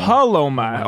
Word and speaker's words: PALOMA: PALOMA.